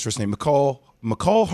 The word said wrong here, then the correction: Mecole: Hartman? Right? – Mecole Hartman.